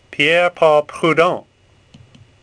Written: Prudhon

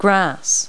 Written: grass

